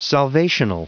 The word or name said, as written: salvational